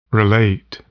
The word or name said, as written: relate